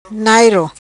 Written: Nairo

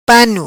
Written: Pano

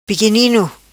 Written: Piquinino

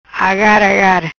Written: Agar Agar

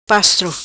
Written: Pastro

